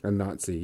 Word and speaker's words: Anansi: How do you pronounce Anansi? The African spider god or whatever her name is. Or his, or whatever it is. – Anansi, yeah.